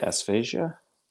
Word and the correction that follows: aphasia: Aphasia, yeah.